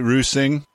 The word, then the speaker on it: Rüsing: Is that right, Rüsing? – And it's Rüsing, but it's hard for somebody from the US or from native speaker from England as well to pronounce this R.